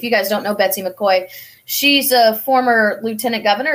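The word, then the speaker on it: McCaughey: I was happy to see my friend Betsy McCoy.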